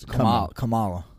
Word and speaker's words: Kamala: Pence crushed Carmella. Carmella, whatever, how you pronounce her name. I'm trying to be disrespectful, but isn't it Carmella? I think it's Kamala.